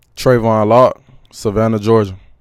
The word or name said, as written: Trevon Locke